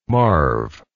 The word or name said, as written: marv